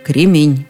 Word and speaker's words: кремень: Кстати, уже в словаре Даля с ударением все было ясно. Только кремень.